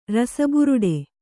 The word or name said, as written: ರಸಬುರುಡೆ